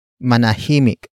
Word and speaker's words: manahimik: Manahimik. Manahimik. Manahimik is spelled as M A N A H I M I K.